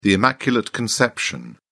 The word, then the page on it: immaculate conception: British English